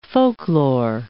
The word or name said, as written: folklore